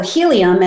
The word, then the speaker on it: Healium: And so Helium, and it's spelled H-E-A-L-I-U-M, like healing, it's virtual healing.